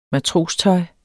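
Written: MATROSTØJET

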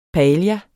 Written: paella

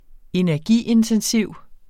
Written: energiintensiv

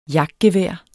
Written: jagtgevær

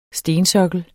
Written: stensokkel

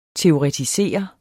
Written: teoretisere

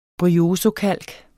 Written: bryozokalk

